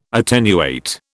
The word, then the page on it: attenuate: British English